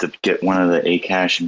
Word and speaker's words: Akash: So it's Akash, the right way to say it.